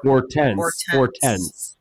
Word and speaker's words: Hortense: Well, it's French, so the H. – Hortense. – Well, it's probably less H sound that I'm saying, because H's are mostly silent in French. It's probably Hortense.